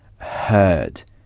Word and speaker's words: heard: H-E-A-R. Et le passé se dit heard. Heard. Ça s'écrit comme l'infinitif, mais avec un D à la fin. Et ça se prononce heard. Heard s'écrit donc H-E-A-R-D.